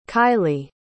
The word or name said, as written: kilie